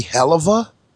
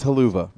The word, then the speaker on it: helluva: Wouldn't it be helluva? – No, it's it, a, it's, it's haluva, Bronx.